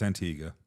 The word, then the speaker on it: Antigua: But then when you said Antigua, or Antigua, how do you pronounce it? – It's Antigua.